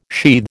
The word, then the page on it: sheathe: American English